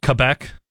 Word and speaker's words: Quebec: He's from Quebec, and he's been a three-year starter. – Sorry, sir, that's pronounced, at SMU. – That's pronounced Quebec. – Quebec? – Quebec.